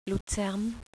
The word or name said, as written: Luzern